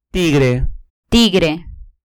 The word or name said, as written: tigre